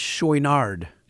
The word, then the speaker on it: Chouinard: It's Chouinard. – Chouinard. – Chouinard. – Okay. – Yeah. It's spelled C-H-O-U-I-N-A-R-D.